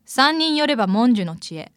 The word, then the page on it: 三人寄れば文殊の知恵: さんにんよればもんじゅのちえ